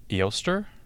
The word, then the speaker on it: Eostre: So there's a Germanic Celtic goddess called, and I'm going to butcher it, Eostre. Germanic Celtic goddess. Her name is spelled E-O-S-T-R-E. E-O-S-T-R-E. So that sounds a lot like Easter. Eostre, Easter, Eostre. But this deity was worshipped as a celebration of spring, and her symbols were eggs and rabbits.